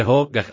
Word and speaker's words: hygge: Dánský výraz se vyslovuje ho, gach a zná ho celý svět,